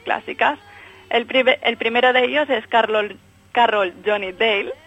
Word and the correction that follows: Daly: es un poco, no, Daly. – Carroll John Daly.